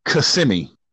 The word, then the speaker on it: Kissimmee: That is pronounced Kissimmee. – Kissimmee. Okay. – Kissimmee, not Kissimmee, Kissimmee.